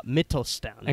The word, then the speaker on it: Mittelstand: And you've commented extensively on small, medium-sized businesses in Germany, and it's called the Mittelstand.